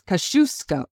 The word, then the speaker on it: Kosciuszko: And there was oftentimes on that tour, listeners, that Beck would correct me on the pronunciation of one of her favorite generals. And that is who, Beck? – Thaddeus Kosciuszko. – Kosciuszko. And I remember I kept saying Kosciuszko.